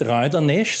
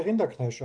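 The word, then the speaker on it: Rinderknech: Während natürlich nicht Reuter-Nesch, sondern Rinder und dann Knesch. Also wie wollen wir es aussprechen, damit die geneigten Hörer wissen, wen wir meinen? – Na, sprich's bitte Rinderknesch aus.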